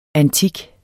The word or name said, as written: antik